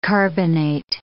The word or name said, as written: carbonate